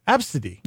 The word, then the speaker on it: ABCDE: A-B-C-D-E. – That's how I remember it. – So, okay. They it's A-B-C-D-E, but of course they pronounce it Absidy.